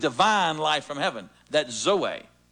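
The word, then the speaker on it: zoe (ζωή): That word life is a word in the Greek called zoe. Zoe. Z-O-E. Long O with a little two dots over the E. Zoe.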